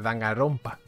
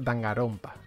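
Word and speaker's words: Danganronpa: Dice Álvaro, pues Dangarompa, Así sería, con la R eh, leve, ¿no? Propia de la propia de los japoneses. Sería en todo, caso Dangarompa.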